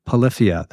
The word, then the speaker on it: Polyphia: Yo, it's Clay Gober and I play bass for Polyphia. – So that was my first question. Polyphia, that's how we're saying it, right? – Yeah.